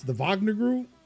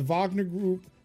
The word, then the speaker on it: Wagner: Russia established a presence in Mali last year through the Wagner Group. – Wagner Group, yeah. – Even though it's spelled with a W, but, you know, Russia.